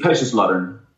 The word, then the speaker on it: Kaiserslautern: Kaiserslautern.